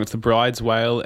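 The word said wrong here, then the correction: Bryde's whale: and the big eye, the reef fish? – Yeah, so it's pronounced brooder's whale.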